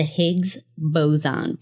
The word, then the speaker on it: Higgs boson: The Higgs boson particle.